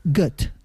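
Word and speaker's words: Goethe: Y según he visto, se pronunciaría Goet. – Goet. – Goet. Mira, bueno, como pues, gota.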